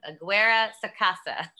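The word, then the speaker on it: Aguirre-Sacasa: Robert Aguesa Sacara. No, I said that wrong. Aguera Sacasa.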